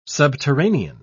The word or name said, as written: subterranean